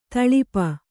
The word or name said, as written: ತೊಳಪು